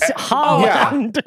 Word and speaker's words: Haaland: It's pretty phonetic. It's just Haaland.